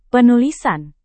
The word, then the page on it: penulisan: Indonesian